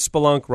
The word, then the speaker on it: spelunk: Just remember that spelunk rhymes with kerplunk.